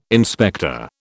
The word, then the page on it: inspector: British English